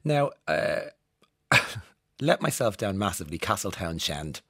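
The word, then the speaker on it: Castletownshend: Loads of people, thank you so much for telling me, don't pronounce the H. And thank you, Jennifer. I never knew there was a H in Castletown Shend, actually. I'll stop saying that because it's going to really annoy you. We never pronounce the H in it though, Jennifer.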